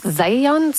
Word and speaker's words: Zajonc: Ouais, j'ai-tu pas dit Zajonc? – Ben, j'avais dit plus Zajonc, mais... – Ah, parce que j'ai prononcé un petit peu à l'anglais. J'ai prononcé mon polonais à l'anglais.